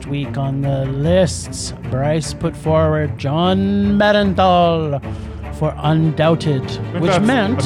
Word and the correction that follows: Bernthal: I think that's Bernthal. – Whatever. – You're putting too many... – Too many oons? – Too many syllables, man. – Bernthal, thank you.